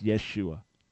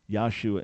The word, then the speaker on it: Yeshua: By the way, that is not the right way to pronounce the name of Jesus in Hebrew. That's Yeshua. Yeshua. Can I say it like 10 times? Yeshua. Yeshua. Not Yahshua in terms of the pronunciation of the name of Jesus in Hebrew / Aramaic, just for information.